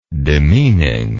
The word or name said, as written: demeaning